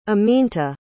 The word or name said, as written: aminta